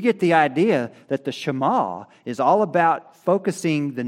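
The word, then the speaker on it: Shema (שמע): It means hear, but the Hebrew word pronunciation sounds like Shema, but it means to obey and to take action, which is what Shema means also, to conform to.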